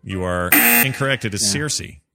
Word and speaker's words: Circe: C-I-R-C-S? – C-E? Lannister. – No, that probably is why I said it that way. Am I saying it wrong? C-I-R? – No, you did it right. – Circe. It's Circe.